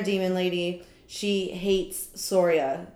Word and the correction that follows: Soraya: Soria. – Soraya. – Soraya. I'm not ever gonna say the name right.